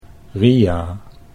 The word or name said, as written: Ria